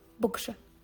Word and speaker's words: بقشة: تنطق بكشة والبعض من أهل الخليج ينطقونها بوجدو طبعا